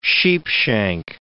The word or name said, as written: sheepshank